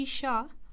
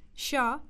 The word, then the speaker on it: Shah: My name is Haji Shah. – Can you spell that for me, please? – Yes, that's Haji, H-A-J-I, Shah, S-H-A-H.